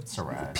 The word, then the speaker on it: psoriasis: Psoriasis.